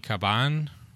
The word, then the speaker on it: Cabán: For the DA candidate, Tiffany Caban. – Yes. – Did he say it right? Did he get it? – He did get it right.